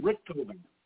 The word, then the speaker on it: Richthofen: Oh, Baron von Richthofen.